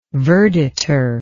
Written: verditer

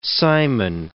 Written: Cimon

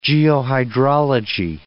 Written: geohydrology

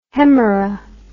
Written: hemera

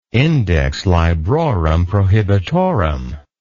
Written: index librorum prohibitorum

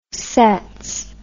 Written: sets

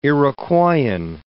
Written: Iroquoian

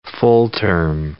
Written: full-term

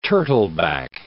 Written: turtleback

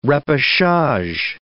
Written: repechage

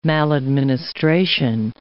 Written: maladministration

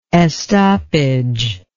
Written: estoppage

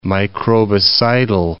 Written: microbicidal

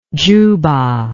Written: giuba

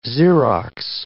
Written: xerox

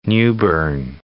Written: New Bern

